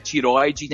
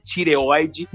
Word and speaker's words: tireoide: Tireoide, né? Que não é tiroide, né? Tireoide.